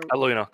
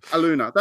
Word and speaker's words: Aluna: I hope I pronounced that right. Is that how? – Aluna, close enough. – Aluna, that's right. Aluna, because after the moon.